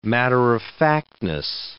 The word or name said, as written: matter-of-factness